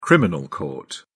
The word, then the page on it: criminal court: British English